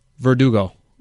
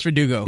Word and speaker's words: Verdugo: Vertigo. You know, it's like Vertigo's what you get when you can't pronounce Verdugo.